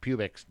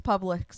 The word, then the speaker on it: Publix: Yeah, it's probably pronounced Pubix down there. – It's Publix, sir.